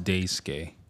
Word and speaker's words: Daisuke: It's pronounced Daisuke. – Is it? – Sorry. – No. – I would have bought it, dude. – I was like, literally everyone has been pronouncing it wrong.